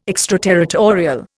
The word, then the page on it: extraterritorial: Indian